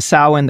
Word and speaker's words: Samhain: Instead of immediately tracing it to Samhain,